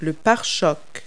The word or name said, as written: bumper